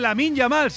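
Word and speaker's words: Lamine Yamal: de banderas y de carteles del hermano de Lamin Yamal.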